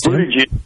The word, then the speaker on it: Buttigieg: Yes, Buttigieg.